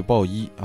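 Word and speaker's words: Bowie: 呃，第二位呢是这个王子 Prince，第七位呢是戴维鲍伊，是大卫鲍伊啊。这个他的那个 last name B O W I E 啊，那个 W 不发音。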